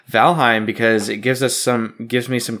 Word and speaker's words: Valheim: I'm still conflicted on how to pronounce that game. – Well, most people pronounce it Valheim.